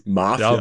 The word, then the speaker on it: Mafia: Mafia. Ja, bei uns war dann, bei, ja, im Deutschen liegt die Betonung eher auf dem F und in Amerika eher Mafia. auf dem A.